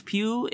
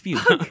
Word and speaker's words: Pugh: Like, Florence Pugh in, is that how you say her name? Pugh or Pug? – I think so. – Pugh, no. – Pugh. – It's Pugh. Florence Pugh.